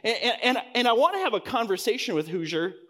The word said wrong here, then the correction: Hauser: How do you say his name? Somebody? Hoosier? Hauser. There you go.